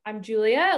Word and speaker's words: Julia: So I'm Julia. A lot of people mess up the pronunciation of my name because I'm Italian. So I get a lot of Giulia. So that's just a funny tip.